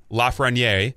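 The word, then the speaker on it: Lafrenière: Alexi Lafreniere. Did I pronounce it correctly, Justin? – That's actually very good. That was very good, yes.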